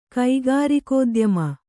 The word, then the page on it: ಕೈಗಾರಿಕೋದ್ಯಮಿ: kaigārikōdyami